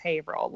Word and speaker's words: Haverhill: There's several of them that when I used to commute on the commuter rail up here, the conductors would laugh at me because I'd be Haverhill is spelled out like Haverhill. And mm-hmm. So I'd be like, is this the Haverhill line? And they're like, it's Haverhill, lady, get on.